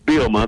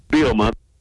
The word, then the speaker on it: Bielema: And by the way, just FYI, because everybody doesn't say it properly, when Brett Bielema first got to town, is it Bielema? That's the proper way to say it, isn't it, Brett? – It's pretty close. It's Bielema. The second he's a little silent, though. Bielema.